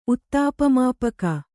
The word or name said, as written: ಉತ್ತಾಪಮಾಪಕ